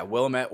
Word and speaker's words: Willamette: Yeah, Willamette, Oregon.